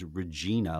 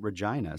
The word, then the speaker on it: Regina: If you look at the word, it really is Regina, like the queen, named after the queen, Latin for queen. Mm-hmm. But they pronounce it Regina. So anyone who hasn't been there or heard that pronunciation, they're going to pronounce it most likely Regina, which is something that causes a lot of chuckles, I'm sure, in Saskatchewan.